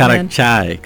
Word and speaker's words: Karak chai: Karak chai. You've got to say it right. – Karak chai.